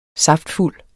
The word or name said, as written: saftfuld